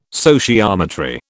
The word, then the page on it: sociometry: British English